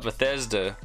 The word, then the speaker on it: Bethesda: So they just bought Bethesda, and all the future games are gonna be day one on Game Pass, including like Skyrim, Doom.